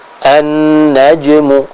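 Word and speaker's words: an-najmu (النجم): An-najmu, pada huruf jim, disempurnakan qalqalahnya dan pengucapannya. Tadi yang kami dengar atau yang masuk studio, suara dari huruf jimnya Takin masih belum sempurna. Artinya masih sedikit mirip dengan huruf C. Contoh an-najmu, nah, bukan naj, tapi naj.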